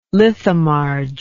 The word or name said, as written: lithomarge